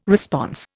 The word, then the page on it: response: Indian